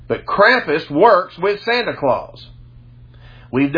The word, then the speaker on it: Krampus: It's K-R-A-M-P-U-S. I pronounce it Krampus.